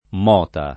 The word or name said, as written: mota